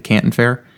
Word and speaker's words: Canton Fair: Is that how you say it? Is it the Canton Fair? – Yeah.